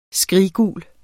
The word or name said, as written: skriggul